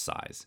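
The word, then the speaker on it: Psy-S: So a lot of times when you see it, it's just like Psy, then dash, then S, but it's pronounced Size, and it's not to be confused with the guy who did Gangnam Style, whose name is just Psy.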